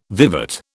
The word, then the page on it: vivat: British English